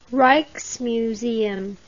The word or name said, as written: rijksmuseum